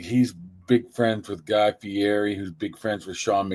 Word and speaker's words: Fieri: However you pronounce his name. – Just Fieri. Come on. We're from Pittsburgh, man. Just say Fieri.